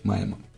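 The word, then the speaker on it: Maemo: Знаешь, я произношу Маема, но на самом деле правильно произносить Маема.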